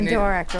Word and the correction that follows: Adirondack: I seem to remember she could not remember how to say Adirondack. – Adirondack. That's right.